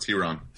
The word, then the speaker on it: Tyron: No, I'm saying it's pronounced T-Ron.